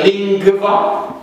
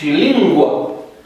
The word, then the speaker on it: lingua: Deci nu se pronunță lingva, ci lingua.